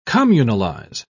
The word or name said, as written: communalize